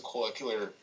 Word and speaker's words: cochlear: Because he doesn't have Lee Abbott didn't know how to fix a cochlear. Is it cochlear implant? How do you pronounce that word? Co-ocular. No. Cochlear. I'll start that again.